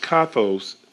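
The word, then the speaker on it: kathos (καθώς): Even in the Greek is pronounced kathos, and listen to what it means.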